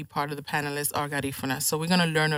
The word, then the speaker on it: Garifuna: You mentioned Garifuna.